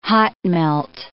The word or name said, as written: hot-melt